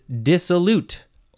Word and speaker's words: dissolute: Next word is dissolute or dissolute. Dissolute, emphasize the first syllable, dissolute or dissolute. But it is spelled with an U-T-E at the end, so I would say dissolute.